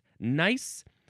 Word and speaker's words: gneiss: Vitreous luster occurs mainly in crystals, but also in massive form, and in grains, is found commonly in gneiss. That's the word G-N-E-I-S-S. Is it pronounced nice? Well, I added the G sound to help you understand that it was different than the word nice, and I have to put on my side lights.